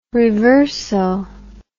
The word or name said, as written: reverso